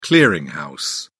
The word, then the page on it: clearing house: British English